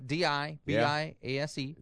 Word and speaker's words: DiBiase: I think is it DeBias? Is that how it's pronounced? Sure. – The Million Dollar Man. – Million Dollar Man on WWE. – I think it's Biasi, but that's okay. – It's close. – Okay. – Either way, DiBiase.